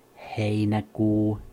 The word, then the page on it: heinäkuu: /ˈhei.næˌkuː/